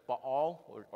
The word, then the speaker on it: Baal: which is such as Baal or Baal, if you want to pronounce it that way, and Asherah, the prophet Elijah did not bow down to them.